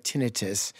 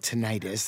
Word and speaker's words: tinnitus: You have tinnitus. It's also called tinnitus. They pronounce it both ways.